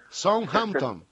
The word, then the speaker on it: Southampton: Bueno, venía a dirigirle Southampton, no sé si lo pronuncio bien, perdón. ¿Cómo se pronuncia? Southampton. – Sí, está bien.